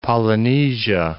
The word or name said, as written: Polynesia